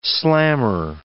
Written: slammer

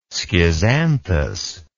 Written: schizanthus

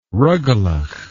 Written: rugalach

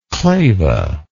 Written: clava

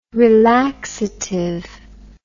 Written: relaxative

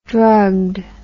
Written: drugged

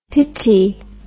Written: pitty

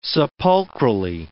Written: sepulchrally

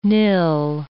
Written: nill

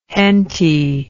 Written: henty

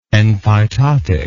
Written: enphytotic

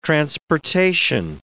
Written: transportation

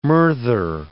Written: murther

